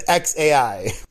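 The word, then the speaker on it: X Æ A-12: And the pronunciation, according to Grimes, the singer, is just X, she says, then A-I, and that's it. X-A-I. – X-A-I? – X-A-I.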